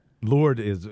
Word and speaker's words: Lourdes: So Lourdes is what the French would say, right? – Right. – They drop the S, yes.